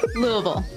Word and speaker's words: Louisville: I said that right, didn't I? Louisville? Louisville? Louisville. – There you go. – Louisville, Kentucky. – Louisville. Yes.